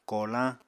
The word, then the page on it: kolañ: Apatani